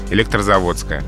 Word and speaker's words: Электрозаводская: А дело в том, что во всех таких географических названиях, образованных от всех заводов, скажем так, сохраняется ударение на «о». Велозавод – Велозаводская, электрозавод – Электрозаводская, автозавод – Автозаводская.